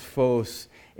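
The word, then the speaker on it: phos (φῶς): So this Greek word is pronounced phos, and it's the Greek word for light in this scripture means to shine.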